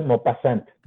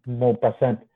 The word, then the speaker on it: Maupassant: se, pronuncia Maupassant, Maupassant.